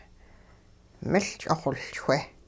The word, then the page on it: miL-ch'ixoLchwe: hupa